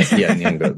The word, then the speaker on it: Nyong'o: I heard that Lupita Nyong'o, right? Do I pronounce mm-hmm. it correctly? – You'd have to ask her. I think, yeah, Nyong'o. That's very close. Very close. Yes.